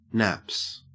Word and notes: naps: Australian English

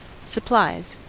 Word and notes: supplies: American English

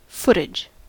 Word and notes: footage: American English

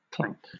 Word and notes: clank: British English

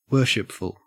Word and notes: worshipful: Australian English

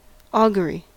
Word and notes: augury: American English

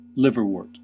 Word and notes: liverwort: American English

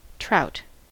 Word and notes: trout: American English

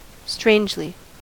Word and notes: strangely: American English

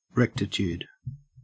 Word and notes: rectitude: Australian English

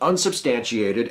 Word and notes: unsubstantiated: American English

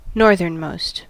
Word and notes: northernmost: American English

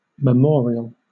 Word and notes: memorial: British English

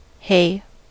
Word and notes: hay: American English

